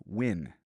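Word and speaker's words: Nguyen: And for those of you who are unsure how to pronounce Bee's last name, I checked. You say it like Win. W-I-N.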